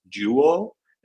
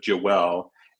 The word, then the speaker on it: Joelle: So I'm pretty sure her name is pronounced Jewel instead of Joelle.